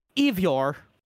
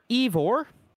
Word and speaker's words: Eivor: You'll be able to play as either a male or female character named Ivor. Ivor. Sure. – I don't know how to say it either. Don't ask me. – E-I-V-O-R. Ivor.